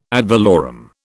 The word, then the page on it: ad valorem: British English